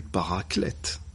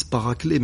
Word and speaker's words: paraclet: Toujours dans l'évangile de Jean, il n'y a pas plus clair que cette citation du paraclète. Beaucoup disent paraclet, mais comme c'est un nom qui n'est pas d'origine française, je préfère citer je préfère prononcer le T de la fin.